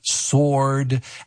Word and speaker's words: sword: And that, and, you know, hopefully your husband smiles at you when he corrects you. Sword. But you look at that word, especially if the English is not your first language, you look at sword and it's like sword, you know.